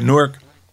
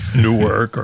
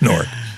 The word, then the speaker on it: Newark: Now, in a city like Newark, they're competing with ethnic, by the way, Newark rhymes with pork or cork. – Yeah. – The way God intended it to rhyme. It's not Newark or, Newark. It's Newark.